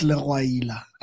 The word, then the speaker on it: Legwaila: Um, I just heard you guys pride yourselves with mispronouncing my surname. it's Leroyila.